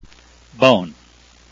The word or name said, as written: beaune